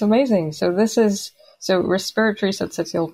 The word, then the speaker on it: syncytial: How do you say that? Second word. – It's respiratory syncytial virus.